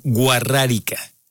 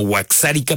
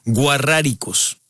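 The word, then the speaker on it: Wixárika: Sí, es la comunidad guarrárica, pero lo difícil de esto es que se escribe con W y X, que es a lo mejor fonéticamente sería como huaxárica, pero ellos se hacen llamar guarráricos.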